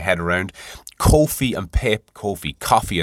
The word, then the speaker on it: Ko-fi: I think it's coffee, it's pronounced. K-O-F-I.